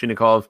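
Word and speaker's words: Chinakhov: As far as I understand, that's how it's pronounced, Chinnikov.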